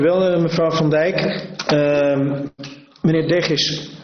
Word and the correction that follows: Degivis-Mas: Degivis-Mas, ik moet dat toch netjes blijven uitspreken om u recht te doen. Meneer Degivis-Mas, Democraten Hilversum, gaat u gang.